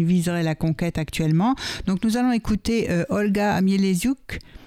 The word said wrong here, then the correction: Mieleshuk: Je prononce bien. Non, Mieleziuk. Mieleshuk, voilà, voilà.